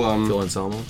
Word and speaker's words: Anselmo: Phil Anselmo.